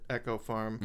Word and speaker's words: Eco Farm: it fell under the collective heading of the name of the farm, which was, it's spelled Eco Farm, but they pronounce it Echo Farm.